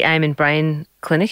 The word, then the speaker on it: Amen Brain Clinic: Now, more recently, you are in the US and were summoned to the Amen Brain Clinic. Have I said that right? – I believe so.